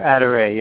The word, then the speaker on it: Aderréy: Aderréy, yes.